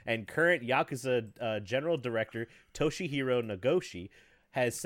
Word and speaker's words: Toshihiro Nagoshi: So, Toshihiro Nagoshi.